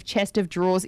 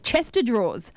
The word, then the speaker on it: chest of drawers: Another common thing that people mispronounce is instead of chest of drawers is Chester drawers.